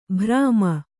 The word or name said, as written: ಭ್ರಾಮ